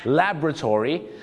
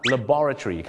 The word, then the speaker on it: laboratory: จะออกเสียงว่า laboratory the laboratory แต่ชาวอังกฤษจะออกเสียงว่า laboratory ครับ laboratory laboratory อเมริกา laboratory